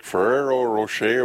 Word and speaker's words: Ferrero Rocher: This, I don't know how to say it because, like, Ferrero Rocher or whatever, however you know, is that how you say it? – Yeah.